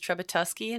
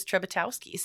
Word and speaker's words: Trezbiatowski: Yeah, you know, my mother-in-law says Trezbiatowski and my father-in-law says Trezbiatowski. So I think either way is all right.